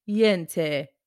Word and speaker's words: Yente: Well, she plays, I'm looking at the pronunciation again, Yente. That's how Google told me to say it, the matchmaker in that production.